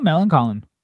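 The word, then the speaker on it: Millencolin: Did I say that wrong? I always say it wrong. – No, Melancholin.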